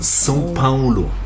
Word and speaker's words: São Paulo: Aber ausgesprochen wird es São Paulo. – Ja, das genau. Das ist sehr nasal.